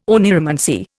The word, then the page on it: oneiromancy: Indian